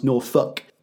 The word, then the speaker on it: Norfolk: Norfolk, spelled Norfolk, but pronounced Norfolk, is a county here in merry old England.